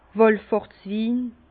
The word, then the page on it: Wàllfahrtswin: Bas Rhin; Herrlisheim